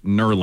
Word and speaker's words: knurling: 800 people have let me know it's pronounced knurling. The K is silent.